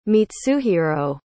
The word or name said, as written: mitsuhiro